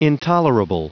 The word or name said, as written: intolerable